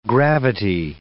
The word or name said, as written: gravity